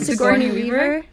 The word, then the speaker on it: Sigourney Weaver: Sigourney Weaver? – You nailed it.